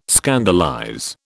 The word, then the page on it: scandalize: British English